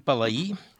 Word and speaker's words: Palai: perquè està accentuada la I, és una... Suposo que deu ser així, no? S'ha de pronunciar Palai.